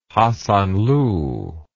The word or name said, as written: hasanlu